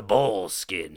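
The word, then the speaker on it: Boleskine: So, I'm going to tell you about the Bullskin House. So, and it's spelled B-O-L-E, Skin House.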